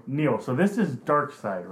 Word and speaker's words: Darkseid: Okay, I'll throw up on you if you pronounce it Darkseid. It's Darkseid. I know him because I peeped a lot of the Justice League cartoons, and he was like always the big villain.